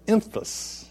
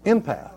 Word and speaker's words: empath: You need to determine if you are an emphas. Emphas, an emphas, comes from the word empathy. Empath.